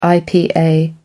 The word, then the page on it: ipa: American English